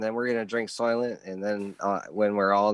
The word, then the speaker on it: Soylent: I'm drinking soil, the vanilla Soylent. Oh, I don't even know how you say it. – You just said it, sort of. Soylent.